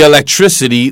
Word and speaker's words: electricity: So that's when the electricity went out. 맞습니다. 바로 그때가 the electricity 발음 주의하세요. electricity, tricity예요. – electricity가 아니고요.